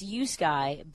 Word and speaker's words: Uski: And that's Yuski, but it's spelled Uski.